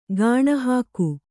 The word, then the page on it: ಗುಣಿಹೊಗು: guṇihogu